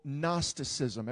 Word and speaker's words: Gnosticism: There was a group of people there in the city of Colossae that were really into this movement called Gnosticism. Everybody say Gnosticism. You'll see the way it's spelled on the screen in a moment. It actually begins with the word G, Gnosticism.